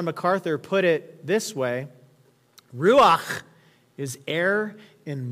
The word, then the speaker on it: ruach: Well, the Hebrew word that's used here for spirit is the Hebrew ruach. Ruach. And no, I'm not hawking a loogie, like that's how it's pronounced.